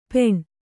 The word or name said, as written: ಪೆಣ